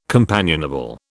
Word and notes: companionable: British English

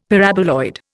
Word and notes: Paraboloid: Indian

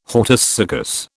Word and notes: hortus siccus: British English

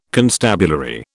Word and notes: constabulary: British English

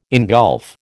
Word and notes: engulf: American English

